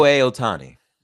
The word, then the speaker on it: Shohei Ohtani: Shohei Ohtani.